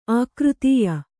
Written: ಅಕೃತ್ಯ

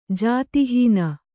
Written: ಜಾತಿಹೀನ

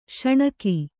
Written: ಶೋಣಕ

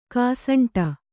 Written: ಕಸಂಟು

